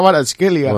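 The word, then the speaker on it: Kvaratskhelia: Bueno, hi ha moltes cas per mig, però crec que la pronunciació és Bicha Baratskhelia. – Bueno, que Baratskhelia diuen els d'allà.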